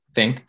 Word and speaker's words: Fink: How do you say his last name? – Fink. Yeah.